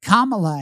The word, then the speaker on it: Kamala: And Goodstein, who did a good job trying to tell Tucker tactfully, hey, her name is Kamala, and if you think about the punctuation comma and add a lot to it, you will get Kamala, and you can pronounce her name correctly.